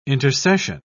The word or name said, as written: intercession